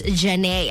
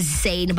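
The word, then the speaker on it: Zhané: I think a lot of us confuse her name because it's spelled as Z-H-A-N-E. So I think all of us would read it as Zane, but Ari, pronounced as Janae.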